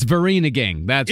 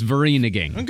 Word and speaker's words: Vereeniging: Is it Vereeniging? – It's Vereeniging. – Is that how it's pronounced? – I did look it up. It's Vereeniging.